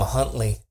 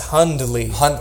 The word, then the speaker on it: Hundley: I love that you guys still call him Huntley. His name is Hundley.